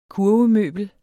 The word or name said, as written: kurve-